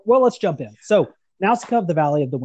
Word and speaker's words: Nausicaä: The name Nausicaä, which you notice has a diéresis over the second A, which means that the name should be pronounced Nausicaä, but is not, of course, honored. That is what that diéresis means. That is a diacritical mark that means that the vowel sounds are separate.